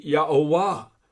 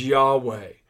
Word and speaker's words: Yahweh: Some say it should be Yahowah or Yahovah, but I tend to believe the correct pronunciation is Yahweh, the personal name of God.